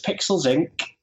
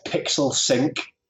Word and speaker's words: Pixels Inc.: Okay, my business, Pixels Inc., and that's, or you could pronounce it Pixel Sync. It's easier to remember, but it's a K on the end.